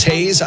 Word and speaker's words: Haze: My name is Haze. I spell it H-A-Z-E, like Purple Haze.